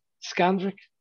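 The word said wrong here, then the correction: Valdes-Scantling: Valdez Scantling. – There we go.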